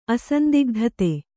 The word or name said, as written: ಅಸಂದಿಗ್ಧತೆ